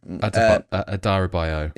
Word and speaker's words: Adarabioyo: Tosin Adarabioyo, I hope I've pronounced that correctly. – Adarabioyo.